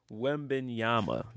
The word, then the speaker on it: Wembanyama: Victor Wembanyama. That's how you pronounce it. – Wembanyama.